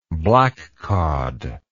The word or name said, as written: black cod